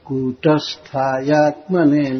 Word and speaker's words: कूटस्थायात्मने: और न पर फिर रुके, अनंतायादिभूताय कूटस्थायात्मने नमः। क दीर्घ उच्चारण, इसमें दीर्घ उकार लिखा गया है, कु कूट ट पर भी रुकेंगे, कूटस्थायात्मने नमः। इस तरह से पढ़ेंगे, पढ़िए।